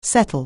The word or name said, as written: settle